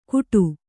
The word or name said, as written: ಗೂಡು